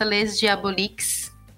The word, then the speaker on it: Les Diaboliques: Mas tem também na minha revista no Instagram, que é a revista Les Diaboliques. Escreve com S, mas pronuncia sem o S, francês.